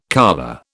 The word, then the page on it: colure: British English